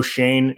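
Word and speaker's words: Schoen: And I believe that's the pronunciation, guys. I don't think it's Shone. – Shane, it's not Shone. Okay. – Yeah, I think it's Shane, even though it doesn't look like it's spelt that way.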